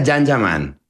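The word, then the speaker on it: Can Yaman: Se escribe Kanyaman, se pronuncia Jan Yaman.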